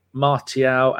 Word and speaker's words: Martial: No, Martial, you don't pronounce the T.